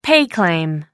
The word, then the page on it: pay claim: American English